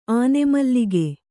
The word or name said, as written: ಆನೆಮಲ್ಲಿಗೆ